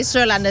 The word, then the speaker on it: Sørlandet: Yes, I would say Sørlandet. Yes. – Okay, ik heb dat goed. Sørlandet is goed.